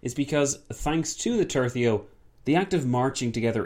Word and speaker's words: tercio: But now we've landed on tercio, which I'm told is correct.